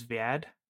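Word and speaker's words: Zviad: It's Z-V-I-A-D, is Viad.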